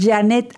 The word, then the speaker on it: Janet: Solamente les va a pedir ahí un código y ustedes van a poner Janet Arceo.